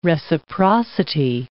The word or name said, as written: reciprocity